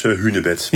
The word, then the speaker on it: hunebed: Volgens de officiële spelling is het alleen met die ene N juist. En er zijn heel veel mensen die nog steeds met 2 N'en uitspreken. Dat is geen schande. Maar ja, de officiële spelling en uitspraak zou dus hunebed moeten zijn.